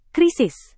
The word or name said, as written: krisis